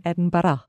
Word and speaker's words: Edinburgh: Well, first of all, learn how to say Edinburgh instead of Edinburgh. – Okay, thank you. Teach me how to say it correctly. – Edinburgh. – Edinburgh. So this is something very important, Edinburgh.